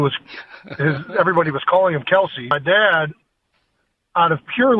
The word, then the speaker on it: Kelce: K-E-L-C-E, it's Kelsey. – It is Kelsey.